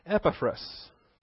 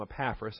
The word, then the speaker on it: Epaphras: For years and years, I called him Epaphras. And then I think it was Robbie Dean or one of those high-powered pastors called him Epaphras. So I've adjusted my pronunciation ever since.